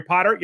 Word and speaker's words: Potter: My name is Jerry Potter. Yes, it rhymes with the boy wizard, and host of the Social Media Marketing Talk Show from Social Media Examiner.